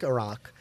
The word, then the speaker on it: Arak: So A-R-A-K. Sorry. Read that spelling again. – Yeah. Arak. – Arak. – Like the country. Iraq, which is right. How you're supposed to pronounce it, and people correct.